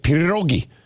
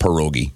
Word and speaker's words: pierogi: Did I say that right? Pierogi. It's not pierogi. Because, I mean, you know, if you're... – I can't roll my R, so it's pierogi to me.